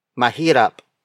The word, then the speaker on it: mahirap: Our next example is mahirap. Mahirap. Mahirap. Mahirap is spelled as M-A-H-I-R-A-P.